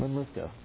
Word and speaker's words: Linlithgow: Linlithgow. Okay, get the English pronunciation of the Scottish town.